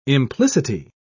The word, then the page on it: implicity: British English